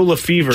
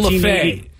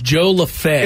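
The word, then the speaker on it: Lefebvre: Joe Lefever. – Joe Lefay. It's spelled Lefever, but it was pronounced Lefay. – Lefay. Wow. – Joe Lefay. – Never guessed that. – L-E-F-E-B-V-R.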